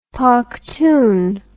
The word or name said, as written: pakhtun